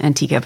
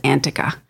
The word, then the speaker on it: Antica: Yes, it's called Antica, which is, I know, strange because it's spelled Antica, but that's how you pronounce it, is Antica.